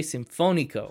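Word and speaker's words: Sinfónico: Nietzsche Sinfonico. – You put the accent on the wrong. There's literally an accent above that O. – Whoops. – That's okay. – Well, then you say it. – Nietzsche Sinfonico.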